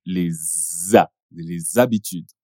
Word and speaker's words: les habitudes: Les habitudes, que se pronuncia les habitudes, os hábitos.